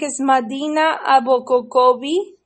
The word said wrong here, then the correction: Abokobi: and then the number. – Abokobi.